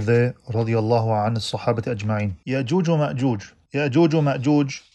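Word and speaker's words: Yajuj wa Majuj: However, they are pronounced in the Arabic way as well, Ya'juj wa Ma'juj.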